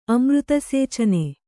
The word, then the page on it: ಅಮೃತಸೇಚನೆ: kannada